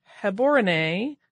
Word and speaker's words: Gaborone: but it's a B-A-T, not a B-O-T. Also, the capital city of Gaborone is pronounced more like Heborone with a guttural G and the accent on the final E. So, I'm guessing Gaborone, and I may be still butchering that, but I'm trying my hardest.